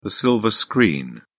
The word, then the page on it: silver screen: British English